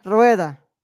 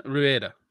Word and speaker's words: Rueda: The film stars Belen Ruda? Belen? I'm trying not to destroy because it's Spanish and I can like pronunciate Spanish. – Belen Rueda. – Belen Rueda. Thank you.